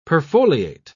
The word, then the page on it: perfoliate: British English